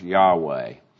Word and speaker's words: Yahweh: You'll hear me say Yahweh, the tetragrammaton, those four letters that represent the name of the Lord and are often pronounced Yahweh.